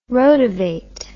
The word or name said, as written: rotovate